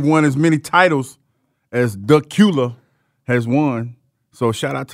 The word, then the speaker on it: Dacula: So it's not Dacula, huh? – It's not. It's Dacula. – Dacula.